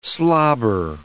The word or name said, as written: slobber